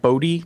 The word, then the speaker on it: Bodhi: Well, first is that it's actually pronounced Bodhi, kind of like the word Jody.